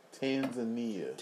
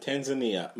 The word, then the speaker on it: Tanzania: Say it with me, bro. Tanzania. – Tanzania. My apologies for mispronouncing.